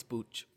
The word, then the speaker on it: booch: I think it's booch like like Buddha.